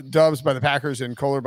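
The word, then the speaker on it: Doubs: Romeo, is it Dubs? – Dubs. Yes.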